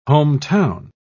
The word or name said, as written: hometown